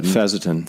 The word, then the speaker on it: fisetin: Yeah, I actually wrote about fecetin, but how do you pronounce it? Fecetin? – Fezetin.